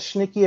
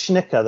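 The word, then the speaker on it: Schnittke: I've heard it pronounced both Schnicka and Schnicke. I've heard it both. I think it's Schnicka though, is how it's pronounced.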